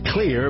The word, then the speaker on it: Xlear: It's pronounced clear, but it's spelled X-L-E-A-R.